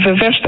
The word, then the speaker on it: ververs: Want je ververst de olie van je auto. – Ja precies. Dat is Ververs. – Ja, verder. – Dus we moeten de klemtoon op de eerste E leggen.